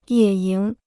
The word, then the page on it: 野营: yě yíng